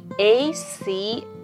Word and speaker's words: ache: ACHE で ache と読む